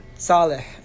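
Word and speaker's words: Saleh (צאלח): So the correct pronunciation is Saleh, and it's actually spelled Tzadi, apostrophe, Aleph, Lamed, Chet. Ezra ben Saleh. So that's how you have to say it.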